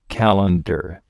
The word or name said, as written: calendar